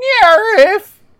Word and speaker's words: NIIRYF: The podcast itself, it's found at @NIIRYFpod on Twitter, and those are the letters for Not If I Reboot You First, and it is pronounced NIRIF.